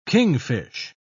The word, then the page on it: kingfish: British English